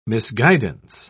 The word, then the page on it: misguidance: British English